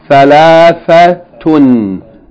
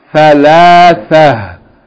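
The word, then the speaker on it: Thalathatun (ثلاثة): Thalathatun. Thalathatun. If we stop on it, we'll pronounce it. Thalathata? Thalatha. With H on the end.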